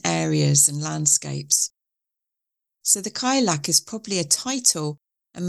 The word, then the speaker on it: Cailleach: Cailleach is an Irish word, and there are several pronunciations, but I tend to go with Cailleach.